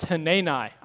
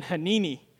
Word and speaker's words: Hanani: He said, it's Hanani. I was saying Hanini, like panini.